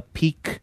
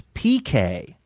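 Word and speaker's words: piqué: I said I there's a T-shirt that I really like from that place, and I was calling it a peak T because it's spelled P-I-Q-U-E. I thought it was like peak your interest. – Yeah. – But listener Ann said when it's fabric, it's PK.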